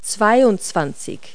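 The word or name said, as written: twenty-two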